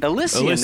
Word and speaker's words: Elysian: Elysian, there you go.